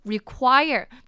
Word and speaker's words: require: Require，那后面这个 Q-U-I-R-E 的部分呢是发 quire 的音 quire， Require， Require。那不知道有没有人对后面这个 quire 的发音感觉很熟悉，因为它跟合唱团这个 C-H-O-I-R quire 的发音是一模一样的，两个弄定一遍的话是 require 跟 choir。